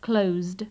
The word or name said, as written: closed